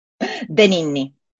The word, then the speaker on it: De Ninni: Danini. – Danini. So it's D-E and then N-I-N-N-I.